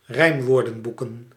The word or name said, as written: rijmwoordenboeken